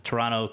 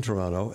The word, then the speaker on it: Toronto: I was in Toronto, and you know I know about Toronto because I don't pronounce the last T.